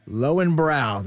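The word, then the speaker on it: Löwenbräu: Is it Lowenbrau? – Lowenbrau.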